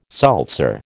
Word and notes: seltzer: American English

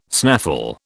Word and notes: snaffle: British English

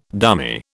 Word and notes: dummy: British English

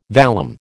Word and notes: vallum: American English

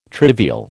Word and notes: trivial: American English

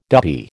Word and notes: duppy: American English

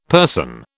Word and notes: person: British English

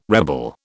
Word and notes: rebel: British English

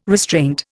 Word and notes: restraint: Indian

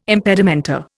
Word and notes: impedimenta: Indian

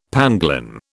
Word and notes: pangolin: British English

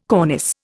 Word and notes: cornice: Indian

